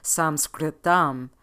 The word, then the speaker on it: Sanskritam: It is my hope that we continue to expand and to bring the language of yoga, Sanskritam, you probably know this as Sanskrit, to the forefront and begin to say its name with its proper pronunciation.